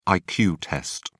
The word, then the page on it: iq test: British English